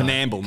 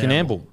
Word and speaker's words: Coonamble: So we've got Coonamble Bottle Shop. – Gonna read this. – Coonamble. – Is that how you say it? – No. Canamble, mate. – Canamble.